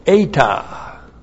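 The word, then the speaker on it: Eta (ἤ): The disjunctive particle eta. Looks like that's just one letter, a long E.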